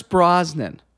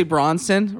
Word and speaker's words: Brosnan: They got old Pierce Brosnan. – Very good. You got his name right this time. – I know. I usually say Bronson, right? – Pierce Bronson.